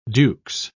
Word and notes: dukes: British English

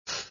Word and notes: -th: British English

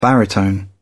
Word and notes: baritone: British English

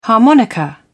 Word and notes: harmonica: British English

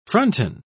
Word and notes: fronton: British English

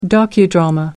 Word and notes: docudrama: American English

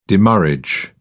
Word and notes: demurrage: British English

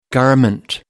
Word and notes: garment: American English

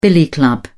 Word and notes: billy club: British English

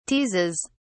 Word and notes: teases: British English

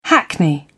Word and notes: hackney carriage: British English

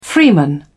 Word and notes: freeman: British English

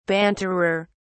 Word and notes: banterer: British English

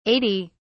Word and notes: 80: British English

